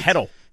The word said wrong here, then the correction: Hertl: Is that how you say it? – Hurdle. – Tomas Hurdle. – Hurtle. – Is there an R in there? Or is it just H-E-R-T-L.